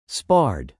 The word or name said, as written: sparred